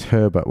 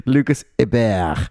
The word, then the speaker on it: Herbert: and like I think Lucas Herbert won, and Hebert. – you pronounced to the world that he was French, Lucas Hebert.